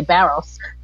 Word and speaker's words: Barros: Well, Christian, I think you pronounce it better than I do, because with my Australian accent, I say Barros.